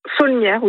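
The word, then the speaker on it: Saulnières: Je me posais la question d'ailleurs, on prononce le L pour Saunières ou je ne sais jamais. – Saunières, oui, on le prononce.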